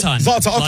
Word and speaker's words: Zlatan: Zoltan. – Yeah, I should call him Zoltan. – Zlatan. Okay. Zlatan.